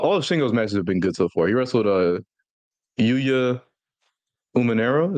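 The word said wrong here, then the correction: Uemura: Is that how you say it? – No, Yuya no? Uamura or Yuyamura. – Oh, I've been saying it so wrong.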